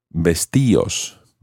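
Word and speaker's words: vestíos: However, you have to add an accent to the I in order to keep that type of pronunciation. Vestios, vestios. This is because the combination of I-O normally is pronounced as one syllable. Think of the word Dios, Dios. It's not Dios, but Dios. So vestios requires an accent.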